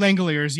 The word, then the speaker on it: Langeliers: Langeliers, yeah.